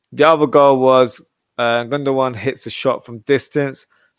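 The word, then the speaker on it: Gündoğan: I'm like, oh, shoot, it's not Gundogan, it's Gundogan. So apologies.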